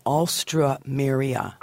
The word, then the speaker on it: Alstroemeria: It's A-L-S-T. – Alstra. – Alstro. So A-L-S-T-R. – Mira. – And then O-E... Maria. So Alstroemeria.